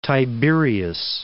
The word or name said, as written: Lake Tiberias